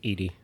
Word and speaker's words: Edie: I took the liberty of getting Eddie McClurg to play Grace. – Edie. Great. – Edie.